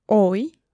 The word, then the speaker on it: hoy: So, that was H-A-B-E-R. So, haber. What you may have guessed by now is that the H, if it starts a word, is not pronounced. Mm-hmm. So, for example, how do you say today? – Hoy. – Hoy. And the spelling is H-O-Y. It's a similar case there. You don't pronounce the H.